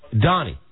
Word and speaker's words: Doni: Donnie. You spell it D-O-N-I. – Don? – D-O-N-I. – D-O-N-I? – Now, don't sign me up for anything.